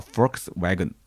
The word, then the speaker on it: Volkswagen: 第二个呢，有人是揪了我一个发音的错误，他说这个 Volkswagen 啊，这个 V 应该是发。呃，F 的音应该叫 Volkswagen，哎，这个真的是大家太严谨了，太严谨了，都是直男啊，你们都是直男。